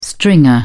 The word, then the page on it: stringer: British English